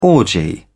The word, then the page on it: orgy: British English